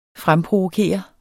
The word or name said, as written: fremprovokere